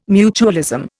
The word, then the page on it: mutualism: Indian